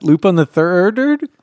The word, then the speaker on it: Lupin the Third: How do you pronounce that? Lupin the Third? – Yes.